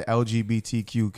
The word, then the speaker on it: LGBTQ: Well, I mean, this focusing on the actual issue that his comments actually offended the LBGTQ yep, exactly. if did I say that right? – LGBTQ. LGBTQ. Yeah.